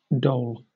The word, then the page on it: dole: British English